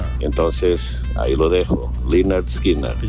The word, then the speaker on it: Lynyrd Skynyrd: Pues yo te puedo asegurar que es así, Lynyrd Skynyrd, porque la Y sí. En inglés se lee I.